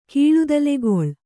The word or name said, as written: ಕೀಳುದಲೆಗೊಳ್